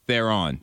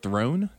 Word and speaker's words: Theron: With Tom Hardy and, help me pronounce her name, Charlize Theron. – Theron. – Yep. – The last name, I always struggle. I'm like, Throne? No, it's not Throne.